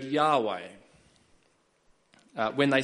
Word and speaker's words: Yahweh: Uh, so um, in Hebrew, it's pronounced Yahweh, and uh,